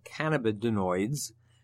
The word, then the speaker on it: cannabinoids: Well, it's one of over 100 chemical compounds that are called cannabinoids. Actually, did I pronounce that right? Cannabinoids, actually. – Yes, that's good.